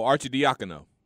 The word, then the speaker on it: Arcidiacono: Ryan, and the last name is A-R-C-I-D-I-A-C-O-N-O. – Oh, Archie Diacono. – Okay. And then Svi, M-Y-K-H-A-I-L-I-U-K. – I don't know that one. I just know Archie Diacono because he was on that Villanova team.